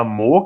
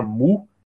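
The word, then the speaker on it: Mø: Temos a cantora Mo, que é aquele, aquele que se escreve com M e um O com risco no meio, que é uma letra nórdica, né? Que na verdade eu descobri que se pronuncia Mu. Então, assim, a cantora Mo, que na verdade é Mu, ela faz um indie pop também que ganhou é, destaque recente.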